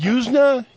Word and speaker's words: Yuzna: How the hell do you pronounce his name? – Oh, hell of a fuck. – Yuzna. Brian Yuzna. – Sounds good to me.